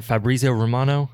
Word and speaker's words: Fabrizio Romano: Is it Fabrizio Romano? Is that how you say his name? – Yeah. – Okay, yeah. So, I mean, I think Mbappe is gone.